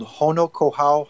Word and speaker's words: Honokohau: And the name of this harbor is called Honokohau Harbor, and it is in Kailua Kona, Hawaii.